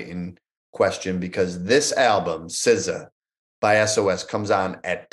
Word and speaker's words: SZA: My number three album of the year is she spells it SZA, and apparently she pronounces it SZA.